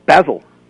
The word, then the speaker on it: Basil: But you see, you're mispronouncing the word. – Oh? – Yes, it is Basil. – Basil? – Yes. And the only reason I know that is because of Fawlty Towers.